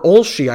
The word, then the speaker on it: Olshey: Uh, Neil Oshie or, Olshey, I apologize. I, I keep mispronouncing his name.